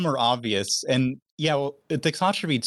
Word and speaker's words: thixotropy: Thixotropy. Is that how you say it? – Yeah, okay. Thixotropy.